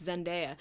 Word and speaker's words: Zendaya: We have to think of the song because they said it right in the song. It's Zendaya. It's Zendaya is Michi and LeBron James is Gwangi.